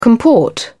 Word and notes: comport: British English

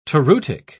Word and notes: toreutic: British English

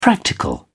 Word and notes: practical: British English

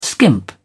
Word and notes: skimp: British English

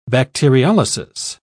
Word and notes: bacteriolysis: British English

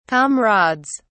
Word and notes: comrades: British English

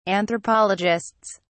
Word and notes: anthropologists: British English

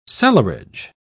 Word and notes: cellarage: British English

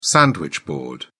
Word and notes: sandwich-board: British English

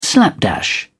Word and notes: slapdash: British English

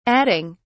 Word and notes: adding: British English

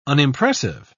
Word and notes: unimpressive: British English

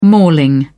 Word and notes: mauling: British English

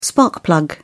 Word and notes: spark plug: British English

